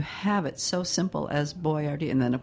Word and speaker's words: Boiardi: But the name is Boyardee, and it's spelled differently: Boiardi.